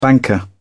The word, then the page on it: banker: British English